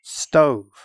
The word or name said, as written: stove